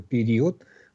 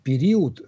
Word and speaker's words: период: Поэтому произносить период с таким явным «у» неверно. Период. Здесь звучит все-таки гласный, почти утративший свою огубленность. И период нет, нельзя.